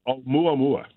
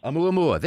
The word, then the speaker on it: Oumuamua: Oumuamu? How do you pronounce it? – Mispronounce that. – Amuse-bouche. I'll go back to amuse-bouche. How do you pronounce that? – Oumuamua. – Oumuamua.